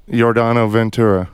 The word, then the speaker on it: Yordano Ventura: Yordano Ventura.